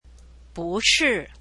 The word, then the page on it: 不是: búshì; bù pronounced as bú when it goes before a forth tone